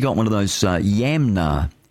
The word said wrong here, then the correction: Yanmar: Yanmar, Yanmar.